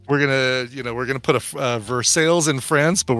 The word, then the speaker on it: Versailles: Merrick says, I'm from Pittsburgh and it's pronounced Versailles.